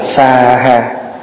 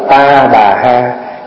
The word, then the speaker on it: sa ha: Và câu cuối cùng là sa ha, có khi đọc là ta bà ha, có khi đọc là khóa ha, thì đọc kiểu nào cũng được, tại vì nó âm từ tiếng Phạn là sa ha, sa ha, sa ha, thì mình âm là xóa ha,